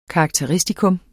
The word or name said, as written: karakteristikon